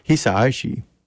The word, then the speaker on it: Hisaishi: I'm sorry, I keep mispronouncing his name. Hisaishi.